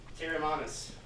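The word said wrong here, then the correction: Teremana: I think it's pronounced Terramanis. – There's no S on it. – Terramana.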